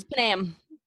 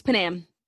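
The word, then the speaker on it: Panem: It's Pan Am. It's Pan Am.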